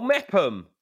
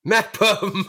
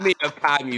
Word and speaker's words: Meopham: M-E-O-P-H-A-M. – Oh, Meppam. – Meppam. Meppam. – Fucking Meppam, mate. Meerfam, you fucking stiff-necked dick. – I'm sorry, I don't know the exact pronunciation of every place in Kent.